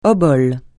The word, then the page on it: obole: francais